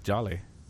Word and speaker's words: Jollye: I wanted to say your last name like with an accent, like Jolly. – Does that sound you good? Can say however you like, but it is just Jolly. It's not that exotic sounding.